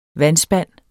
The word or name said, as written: vandspand